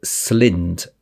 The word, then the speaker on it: Slynd: Yeah, so this is drospirenone, or SLYND, S-L-Y-N-D.